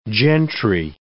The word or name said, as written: gentry